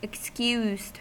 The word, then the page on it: excused: American English